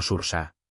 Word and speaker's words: Saoirse: El nombre SAOIRSE proviene del irlandés y significa libertad, pronunciado fonéticamente como SURSA.